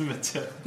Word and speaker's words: Mathilda: It's spelled Mathilda, but it's pronounced Matilda.